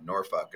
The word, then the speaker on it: Norfolk: Also, how do you say it? N-O-R-F-O-L-K? – Norfolk. – Norfolk. Norfolk. So you basically say fuck when you say the city.